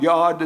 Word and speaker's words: yod: So when we look at the Hebrew words spelling the secret name of God, if you were to look at them, a jod would have like a J, like a fish hook. J-O-B, Y-O-B, jod, yod is what it's pronounced about the same.